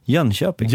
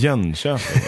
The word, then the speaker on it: Jönköping: Men Jönköping, det är inte Jönåker. – Vad säger man då? – Jönköping. – Jönköping. Det är, Jönköping.